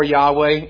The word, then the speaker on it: Yahweh: Jehovah or Yahweh, either pronunciation is acceptable.